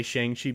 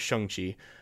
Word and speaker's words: Shang-Chi: I want to say Shang-Chi because I'm a stupid American, but it's Shang-Chi, the way it's pronounced.